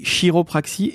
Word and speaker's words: chiropraxie: Il faut savoir écrire et surtout lire chiropraxie parce que tu l'as dit, ça s'écrit C-H-I-R-O-P. R-A-X-I-E. Ça s'écrit chiropraxie et ça se prononce chiropraxie.